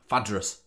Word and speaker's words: Phaedrus: Yeah, Phaedrus.